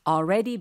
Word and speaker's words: already: 그러니까 L 발음 AL에 너무 이제 신경 많이 쓰시지 마시고 어차피 뒤에 R이 있으니까 already 하고 바로 R로 발음을 넘어가셔도 좋겠습니다.